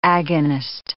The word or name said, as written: agonist